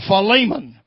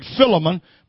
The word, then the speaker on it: Philemon: Give me one more, Sean, and Philemon, 1:1. Some people pronounce that Philemon, but it's actually pronounced Philemon.